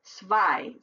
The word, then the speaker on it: zwei: If it's E, I, the I is last, it's pronounced I, like zwei, Z-W-E-I, zwei.